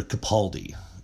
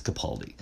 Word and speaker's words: Capaldi: I keep saying his name wrong, and I want to make right of it. Peter Capaldi, I believe it. Capaldi. I was saying Grimaldi. It's not that, it's Capaldi, I believe.